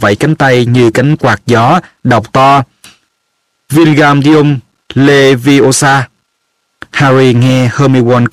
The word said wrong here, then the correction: Wingardium Leviosa: cào nhau: Bạn đọc sai rồi, bạn phải đọc là Wingardium Leviosa mới đúng, kéo dài chữ ga ra một cách rất là duyên dáng.